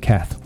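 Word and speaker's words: Kath: My name on all the social networking platforms is Ian Cath. One word. And remember that Cath is spelt with a K, not a C like some people think.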